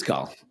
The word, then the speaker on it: Gall: Is it Gall? – It is Gall, yes. – Okay. It's not, because I used to call you Jordan Gall, but that's not, because that's how it's spelled, right? – That's right. I heard you pronounce it different.